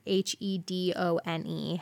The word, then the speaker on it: Hedone: I came upon came upon the Greek goddess Hedone. I think I'm pronouncing that right. H E D O N E, Hedone.